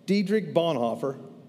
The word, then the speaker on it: Dietrich Bonhoeffer: Dietrich Bonhoeffer, did I say that right? I did? Thanks, Brett. I was close.